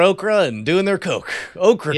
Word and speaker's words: Ocracoke: Do you know an island off the coast of North Carolina called Ocracoke? – Oh, sorry, spell that? – O-C-R-A-C-O-K-E, Ocracoke.